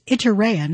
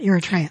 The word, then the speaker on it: Iturea: By the way, I mispronounced. It's Iturea, not Eritrea.